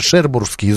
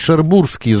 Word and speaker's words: Шербурские: Нет, спасибо тебе за то, что ты наконец-то правильно сделал ударение в названии этого фильма, потому что все говорят «Шербурские зонтики», да? А на самом деле это «Шербурские зонтики», потому что от названия, значит, местности Шербур, вот, да, вот отсюда.